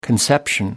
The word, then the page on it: conception: American English